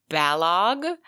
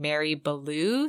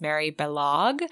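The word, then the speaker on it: Mary Balogh: So Sarah graciously informed us that the correct pronunciation is Balog. She says it rhymes with Kellogg. We had been saying Mary Ballou, so I believe it is Mary Balog.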